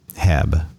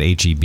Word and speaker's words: HEB: Heb, but we call it HEB. Mm-hmm. and not Heb and not Heeb, which I don't take offense to because it's just a mispronunciation.